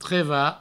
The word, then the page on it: trevar: treva